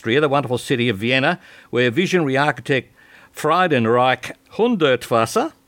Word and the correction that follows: Friedensreich Hundertwasser: how's that, Justine? Friedenreich Hundertwasser, how do you say it? – It's Friedensreich Hundertwasser.